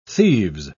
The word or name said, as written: thieves